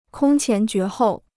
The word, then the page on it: 空前绝后: kōng qián jué hòu